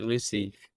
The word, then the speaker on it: Lucea: It's set in a huge amounts of land and acreage on the coast near Montego Bay, in a place called Lucy, spelled L-U-C-E-A, but we pronounce it Lucy.